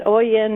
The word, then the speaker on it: Oian: And Oian, oh, nobody ever pronounces right or gets it right, but you did, so uh-huh. I'm impressed. – Yeah. And it's O I A N.